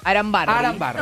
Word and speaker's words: Arambarri: Arambarri.